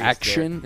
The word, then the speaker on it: Action: I will tell you, it's pronounced Action with like three exclamation points.